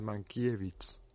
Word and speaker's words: Mankiewicz: En Polonia solo existe, en polaco, solo existen palabras graves o llanas. No hay esdrújulas ni agudas. De modo que es Mankiewicz. Mankiewicz. Mankiewicz. Y se pronuncia tal y como se escribe, un poco la alemana.